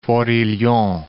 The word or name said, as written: Forillon National Park